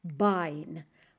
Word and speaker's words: Bein: Das Bein. Bein. Se escribe, ojo, B-E-I-N. Das Bein.